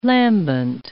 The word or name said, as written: lambent